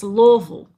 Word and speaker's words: Louisville: It's not Louisville. It's Louisville.